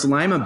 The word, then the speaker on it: Lima: And of course, Lima beans. Well, you know, it's not pronounced Lima like the city in Peru.